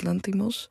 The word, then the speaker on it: Lanthimos: Yorgos Lanthimos.